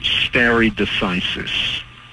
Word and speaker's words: stare decisis: We're going to talk about stare decisis. Stare decisis. Now you say, "Well, what in the world is that?" I'm going to spell it, because it's not pronounced as it looks. It's spelled S-T-A-R-E. That's the first word, stare. And decisis, D-E-C-I-S-I-S. That's S-T-A-R-E.